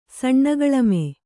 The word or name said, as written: ಸಣ್ಣಗಳಮೆ